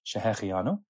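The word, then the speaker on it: shehecheyanu: Just as a point of grammatical and halachic significance, the word shechiano, you'll note, the first three letters have a segol, those three dots before them. So the pronunciation is she-he-che-yanu. You see other pronunciations, you hear other pronunciations all the time, but say it properly, please, she-he-che-yanu.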